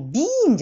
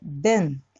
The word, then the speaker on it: been: 次のところは、 BEEN って書いてるんだけど、 EE は I に点々の発音記号じゃなくて、 I だけです。つまり、 Bean じゃなくて、 Bean って言うとお豆さんになっちゃうので、 Ben、 With Ben、音楽わかんない人たちもいて。